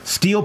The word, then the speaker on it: Stihl: Steel Power Tools, it's spelled STIHL.